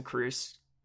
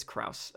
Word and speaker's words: Kraus: And he's up there for me now with like Lawson Kruss, or Kraus, however you want to pronounce his last name. It's Kraus.